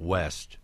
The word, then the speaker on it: Ouest: You worked in restaurants all around town, but when you opened up West, which we should point out is spelled O-U-E-S-T, the Upper West Side was kind of a culinary wasteland.